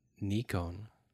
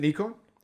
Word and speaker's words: Nikon: And she's like, what about Nikon?